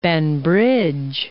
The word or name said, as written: Banbridge